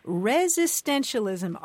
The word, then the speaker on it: resistentialism: Resistentialism, resistentialism.